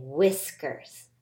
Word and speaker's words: whiskers: We say whiskers. Whiskers. You got to be careful. You don't want to overpronounce that H sound and get whiskers. It's whiskers.